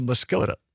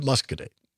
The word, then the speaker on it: Muscoda: Some people will say Muscoda, which is incorrect. – It's spelled like that. – It is not correct. It is Muscogee.